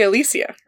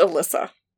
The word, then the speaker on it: Alicia: Because I say Alicia, right? Yeah, that's how it's spelled. Right. It's pronounced Alyssa.